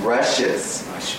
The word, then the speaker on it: rushes: You all know you have to be very careful with this pronunciation, rushes, because there's a word in English, rashes, with an A, R-A-S-H-E-S. R-U-S-H-E-S are the we, or the grass, straw, that's used for tatami.